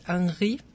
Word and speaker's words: Henri: Y bueno, pues es que sí, él, el protagonista, que bueno, me imagino por ser francés, es Henri, es como se debería de pronunciar, pero bueno, Henry para que se entienda mejor.